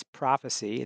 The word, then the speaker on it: Profasee: So, by the way, a lot of people think it's Profacy. It's Prophecy. It's spelled P-R-O-F-A-S-E-E.